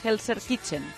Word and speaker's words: Gelsenkirchen: Y hoy De La Fuente ya ha empezado a analizar Italia, que es nuestro próximo rival el jueves en Gelsenkirchen.